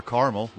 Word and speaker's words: caramel: Neither of them are caramel, which is the correct way to say it.